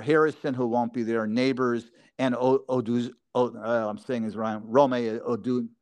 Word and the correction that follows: Odunze: Now I can't say his name, Odunze, whatever.